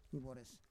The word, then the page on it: ivores: Roon